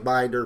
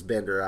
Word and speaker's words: Binder: Beck, as you pointed out, Brian, and one of his primary Golden Age writers, Otto Bender, looks like Binder, pronounced as Bender, I believe, who went on to write a lot of Superman stories and co-create Supergirl.